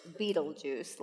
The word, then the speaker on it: Betelgeuse: The brightest star in the Orion constellation, pronounced Betelgeuse, like the movie.